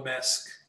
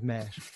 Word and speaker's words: Maersk: Remind me, how do I pronounce Maersk? – It's A.P. Muller Maersk. Yeah. Yeah, it's a bit of a strange Maersk, right? So, yeah, pretty good. – Because if I don't check, I'll just say it like 20 different ways throughout the whole podcast, and that'd just be embarrassing. So we'll go with Maersk.